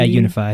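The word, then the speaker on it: UniFi: How do you pronounce it? Unify, Ubiquity. – Yeah, Unify. I think Ubiquity Unify. Yeah, you got it.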